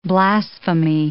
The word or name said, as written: blasphemy